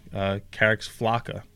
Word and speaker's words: Carex flacca: Well, they put out a special bulletin today that they have stopped selling Carex flacca. Is that how you pronounce it, Frank? – I believe so, yeah. – F-L-A-C-C-A.